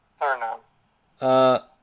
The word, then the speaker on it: Suriname: 104, is it Suriname? Is that how you say it? – Suriname.